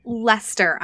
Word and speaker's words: Leicester: Leicester.